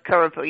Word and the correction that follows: krupuk: Oh, krupuk.